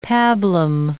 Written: pablum

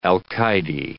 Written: alcaide

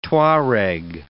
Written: Tuareg

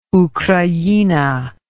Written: ukraina

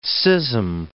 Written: schism

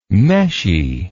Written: meshy

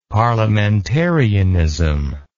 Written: parliamentarianism

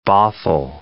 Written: Bothell

